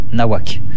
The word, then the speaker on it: Nahuatl: Ça s'écrit Nahuatl, T-L, Nahuac.